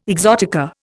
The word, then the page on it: exotica: Indian